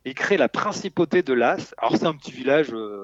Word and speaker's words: Laas: Et le village de Laas, ça s'écrit L-A-A-S, dont le maire se demandait un peu comment redonner du dynamisme à son village.